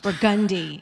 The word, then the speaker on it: Burgundy: And we turn the corner onto Burgundy. – Burgundy? – That's how it's pronounced. – Burgundy.